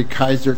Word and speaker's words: Kyser: And members, my name is Jerry Kaiser, K-Y-S-E-R.